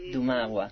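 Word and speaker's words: Dumała: ¿Cómo se pronuncia? Porque tiene esa L con palito. Dumagua.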